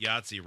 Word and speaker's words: Yahtzee: It's weird that Yahtzee rhymes with Nazi.